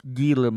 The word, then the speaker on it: Gila: It's now pronounced the way it's spelled. It's spelled G-I-L-A. So it looks like Gila monster, but I believe it's pronounced Gila.